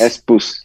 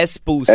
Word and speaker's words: S-Pulse: É Shimizu Pulse? S-Pulse. É S-Pulse. É S-Pulse, é isso. Cara, falando de nordestino, a gente, vamos, a gente voltou lá pro início da nossa conversa.